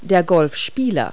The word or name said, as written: golfer